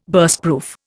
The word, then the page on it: Burstproof: Indian